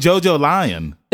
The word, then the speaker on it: JoJolion: And then the final part, which is JoJo Lion, and it's pronounced JoJolion.